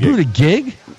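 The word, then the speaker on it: Buttigieg: Yeah, I think it's Budigig. – Budigig? – Yeah.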